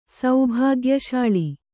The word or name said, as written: ಸೌಭಾಗ್ಯಶಾಳಿ